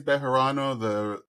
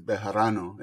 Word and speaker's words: Bejarano: The easy way is Bejarano. The correct way is Bejarano, if you have it in you, but Bejarano is fine. B, I'll accept B.